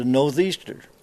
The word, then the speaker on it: northeaster: They call it a northeaster, because that's what it is, and that's how you say it, and that's how you spell it or write it.